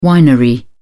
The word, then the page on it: winery: British English